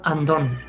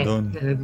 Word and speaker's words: Undone: Mira, he encontrado la serie de la que hablamos, se llama Andón. Undone, Andón. Dicho como suena.